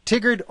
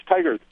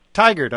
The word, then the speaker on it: Tigard: And let's talk from Tigard, Oregon. – Hi, that's Tigard. – Tigard, okay. I apologize for the mispronunciation.